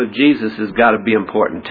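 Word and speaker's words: Jesús: In Spanish it's Jesus, but Jesus is just the Spanish pronunciation of the same alphabetical letters that are Jesus. It's just that Spanish doesn't have a J sound, so that's hey. So it's Jesus instead of Jesus.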